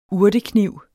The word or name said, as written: urtekniv